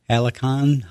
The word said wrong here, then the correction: Halcyon: hosta? – Oh, I don't know. Halcyon. – Halcyon, okay.